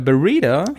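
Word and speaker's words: Barreda: Es gab einen Tagessieg und der war äh, von äh, dem Honda-Fahrer John äh, Berida. – Jo. Wird das so ausgesprochen, ja, ne? Jo, so wird das ausgesprochen.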